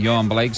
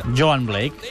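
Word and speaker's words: Yohan Blake: Em sembla que aquest act no l'hem d'aspirar, sinó Joan. que Joan Blake seria la pronunciació correcta segons el company de la televisió jamaicana. Joan Blake.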